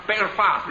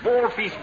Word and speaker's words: Belfast: By now, it was Foreign has been chain slated and like in Belfast. This is terms continually mispronounced as Ballfeast, Balfest, so on, that sort of thing, and eventually Liverpool.